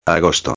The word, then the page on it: agosto: espanhol